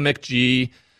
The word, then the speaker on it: McGyver: It's spelled MCGYVER. So I call him Mick G.